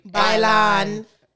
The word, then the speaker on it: Bailon: Bailon. Like it's dance in Spanish. – So how say it again? – Adrian Bailon. – Bailon. – Yes. – 1, 2, 3. – Bailon.